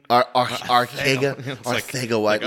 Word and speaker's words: Arcega-Whiteside: Arcega-Whiteside, but they all have to do that like Spanish pronunciation. – Arcega-Whiteside. Like, oh, God. It's like Ibiza.